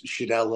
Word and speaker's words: Scherdel: Yeah, it's Scherdel.